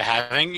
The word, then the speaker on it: halving: The halving. – The halving. – Haveling. How do you say it? – The halving. You were right the first time.